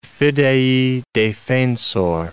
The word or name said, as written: Fidei Defensor